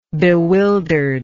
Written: bewilderedness

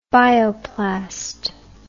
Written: bioplast

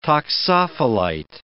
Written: toxophilite

